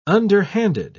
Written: underhanded

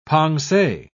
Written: pensee

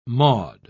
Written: Maud

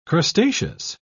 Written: crustaceous

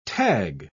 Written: tag

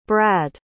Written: bradd

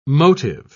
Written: motive

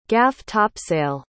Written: gaff-topsail

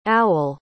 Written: aul

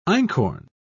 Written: einkorn

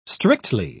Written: strictly

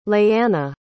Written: leighanna

